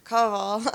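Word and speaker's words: Kowal: Because if you remember Marcus Cowell being on here, Cowell, I think I was the proper way to pronounce it.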